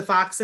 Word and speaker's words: Foxx: I do want to say too that she said that she used the Foxx and Azrin method and that it absolutely worked.